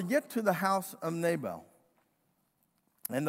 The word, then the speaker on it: Nabal: His name was Nabal, N-A, long A, B-A, long A-L.